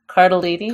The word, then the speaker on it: Cardellini: I say Cardellini. – Cardellini.